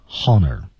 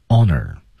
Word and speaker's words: honor: 可千万别读成 honor 啊，h 不发音，honor present 啊，我们看这句话的关键词是这些，我们注意停顿，citizens of the valley peace，停顿，it's my great honor，停顿，to present you，停顿，然后后面每一个单词停顿的一次，tigress， viper， crane， monkey。